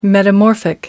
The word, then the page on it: metamorphic: American English